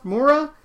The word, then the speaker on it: Moura: Wagner Moreau, I'm saying his name wrong, Moura, has been tapped to star opposite Elizabeth Moss in Shining Girls at Apple TV.